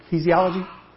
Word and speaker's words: ecclesiology: I can't even pronounce the word. Ecclesiology? Is that right? Close enough, close enough.